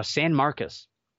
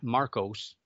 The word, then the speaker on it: San Marcos: San Marcos, not Marcos. It's spelled S-A-N-M-A-R-C-O-S, but it's San Marcos, okay?